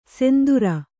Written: ಸಿಂಧುರ

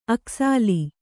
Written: ಅಕ್ಕಸಾಲ

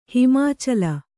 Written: ಹಿಮಾಚಲ

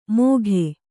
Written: ಮೋಘೆ

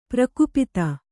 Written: ಪ್ರಕುಪಿತ

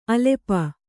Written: ಅಲ್ಪ

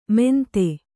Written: ಮೆಂತೆ

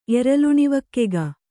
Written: ಎರಲುಣಿವಕ್ಕೆಗ

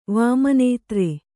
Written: ವಾಮನೇತ್ರೆ

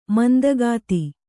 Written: ಮಂದಗಾತಿ

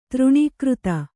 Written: ತೃಣೀಕೃತ

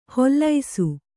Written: ಹೊಲ್ಲೈಸು

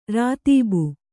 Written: ರಾತೀಬು

